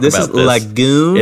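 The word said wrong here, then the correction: Lagaan: Did I pronounce that right? Lagan? – Lagan. I think it's Lagan. – Yeah, it's two A's. – That's how they say it in the movie.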